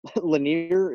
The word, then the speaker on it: Lanier: Um, first pick, Bob Lin- Lanier. Is that how you uh, pronounce it, Carson? – Yep.